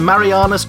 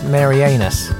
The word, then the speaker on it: Marianas: And together we have trawled the depths of the Marianas Trench to bring you two unidentifiable octopi from the mists or depths of time for you to decide which is most luminous. – I'm pretty sure it's pronounced Marianas.